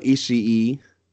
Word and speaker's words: Ishii: Tomohiro Ishii.